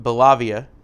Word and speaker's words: Bellavia: Army Staff Sergeant David Belavia. I think I said that right. B-E-L-L-A-V-I-A. – Yeah.